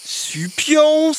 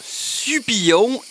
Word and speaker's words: Supion: Il y avait tout d'abord Sapinou, suivi de Supion, certains l'ayant écrit avec deux L, ce qui se prononce alors Supillon et s'écrirait comme Serpillère.